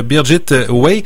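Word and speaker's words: Birgit Weyhe: Deuxième titre, Anne-Marie Olivier, on parle de La Ronde, un titre de Birgit Way chez Cambourakis. – Voilà, tu l'as bien prononcé, j'étais pas sûre de bien le prononcer parce qu'elle est allemande, cette auteure.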